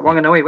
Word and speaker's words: Wanganui: in Wanganui, New Zealand. – Oh, yeah, Wanganui.